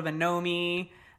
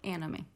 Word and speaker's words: Anomie: We get like a lot of Anomi, Anami, blah, blah, blah. But it's Anomi? – Anomi. Yeah. Okay.